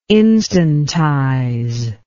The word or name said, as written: instantize